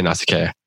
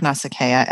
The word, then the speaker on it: Nausicaa: I want to talk about Calypso, and in particular, I want to talk about Calypso versus or compared to Nausicaa, or how do you guys say her name? I say Nausicaa. – I like Nausicaa as the I think it's the prettiest flow. – Okay. Okay, we'll go with that then.